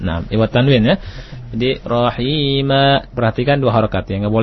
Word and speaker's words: رحيما: طيب نعم رحيمanya mesti mustaqim akhi ya mustaqim kalimat rahima terus bacanya kuat rahima seperti itu